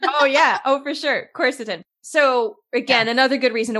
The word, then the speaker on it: quercetin: I was saying it wrong. Quercetin.